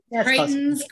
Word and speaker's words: Greitens: So the nominees again for Bitch Ass of the Week, Ginny Thomas, Eric Greitens, did I say that correctly? – Greitens, Greitens, Greitens, Katie Turr and Bill Maher.